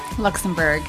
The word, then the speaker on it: Luxenburg: Her name is Brandye and is it Luxenburg? – Luxenburg. – Perfect. – That is correct. – It's phonetic, just how it's spelled, guys.